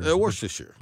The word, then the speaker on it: Worcestershire: Worcestershire. – What say it again? – Worcestershire.